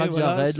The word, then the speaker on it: La Vie à Red: Le premier, donc, ça a été Crasheur de Flotte, suivi de La Vie à Red, si je prononce bien. – Exactement, okay, La Vie à Red, La Vie est dure en créole lyonnais.